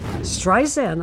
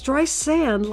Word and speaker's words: Streisand: Streisand, I said. My name is with a Z. It's Streisand, like sand on the beach. Now, how simple can you get? Sand on the beach. Streisand.